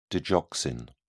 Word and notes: digoxin: British English

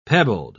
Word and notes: pebbled: British English

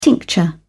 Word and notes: tincture: British English

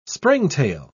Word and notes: springtail: British English

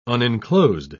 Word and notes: unenclosed: British English

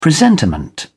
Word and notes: presentiment: British English

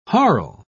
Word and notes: harle: British English

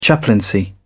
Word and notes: chaplaincy: British English

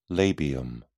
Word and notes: labium: British English

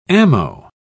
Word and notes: ammo: British English

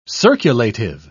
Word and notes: circulative: British English

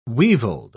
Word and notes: weevilled: British English

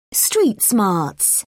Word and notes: street smarts: British English

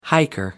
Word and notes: hiker: American English

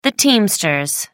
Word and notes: teamsters: American English

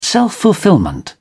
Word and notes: self-fulfilment: British English

